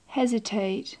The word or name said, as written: hesitate